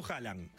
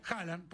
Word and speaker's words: Haaland: ¿Cómo lo pronuncias vos? – Yo digo Haaland. – Haaland, perfecto. Haaland.